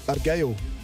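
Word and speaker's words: Argyll: Where I'm from in Scotland, you would pronounce it Argail. But in every accent it's a bit different. So in German it's Argail.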